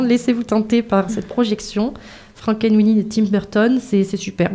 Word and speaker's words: Frankenweenie: Alors, on écoute Frankenweenie, comme ça, c'est comme ça qu'on prononce.